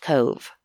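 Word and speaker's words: Cobh: It's C-O-B-H is the city. I think it's pronounced Cove, like a cove.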